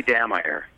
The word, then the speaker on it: Dammeyer: You know, my last name gets pronounced like lots of different ways. I liked the way you pronounced it. It made me sound very she. But it's actually Dammeyer. – Dammeyer, okay. – Much more boring in German rather than she in French.